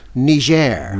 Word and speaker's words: Niger: I have always been familiar with the country, that's spelled N-I-G-E-R, and I always thought it was Niger. In my whole life, it was Niger. Then all of a sudden, about a week ago, it becomes Niger.